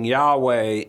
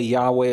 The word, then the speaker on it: Yahweh: We're on pretty solid ground to say Yahweh is the way they pronounce it, but ultimately we don't know.